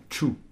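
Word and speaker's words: ĉu: N'oubliez pas que ce C, chapeau se prononce tch, et donc C, chapeau, U se prononce bien tchou.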